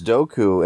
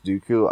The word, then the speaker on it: Dooku: I thought it was Dooku, and for a while, oh, Dooku, that's pretty cool. When I found out it was actually pronounced Dooku, I then hated it.